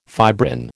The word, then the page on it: fibrin: American English